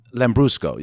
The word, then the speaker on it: Lambrusco: I always forget how to pronounce it. – Oh, what, the Lambrusco? – Lambrusco, yeah.